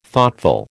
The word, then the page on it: Thoughtful: American English